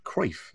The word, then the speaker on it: Cruyff: Don't mispronounce it like every other Englishman, please. – It's Cruyff.